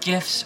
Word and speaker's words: GIF: Those little animated pictures on the internet, are they called GIFs or GIFs? – Well, the G stands for graphics. That's a hard G, so I say GIF.